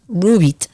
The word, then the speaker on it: Roubit: Su nombre es Rubit. Se escribe R-O-U-B-I-T.